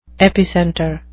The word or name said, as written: epicenter